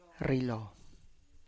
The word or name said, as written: rrilo